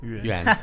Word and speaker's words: Yuan: Shih and Greg Yen. – Yuan. – Oh, close. – See, I did say it wrong.